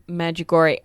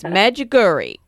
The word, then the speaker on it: Medjugorje: So for us, it's Medjugorje. That's yep. – That's how we pronounce it. – Yeah. – But we know that that's not how it would be pronounced locally.